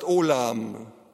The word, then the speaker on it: Olam: Und das obere Wort heißt, das hier heißt Alam, das obere Wort heißt Olam. Es sind dieselben Buchstaben, es wird nur anders ausgesprochen, weil es im Hebräischen ab dem Mittelalter noch Punkte gab. Das sind also die Vokale bei uns. Da wird dann oben ein Punkt hingefügt und dieser Punkt ist die O-Aussprache im Hebräischen.